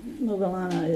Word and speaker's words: Moggallana: There was a Buddha had a disciple named Moggallana, Moggallana, Moggallana, I pronounce Moggallana Mugalana is uh, also Mulian in Chinese.